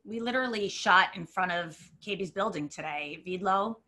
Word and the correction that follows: Vibol: am I saying his name correctly? Vidlo? – Vibo. – Oh. – V-I-B-O-L, yeah. – Oh, Vibo. I said it wrong today.